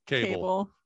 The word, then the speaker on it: Cabell: You got to say cable.